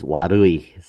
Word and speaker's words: warui: However, it actually comes from the Japanese word Warayu, which is bad. – I can pronounce that as Warui. So yeah, it basically is, as you said, it's Japanese word for bad, Warui.